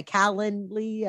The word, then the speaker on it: Calendly: Okay, Calendly, I say that correctly. Yeah.